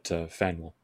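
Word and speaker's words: Faneuil: So before I give Mr. Fanuli, am I saying that right, Fanuli? – It's like Daniel, but Fanuel.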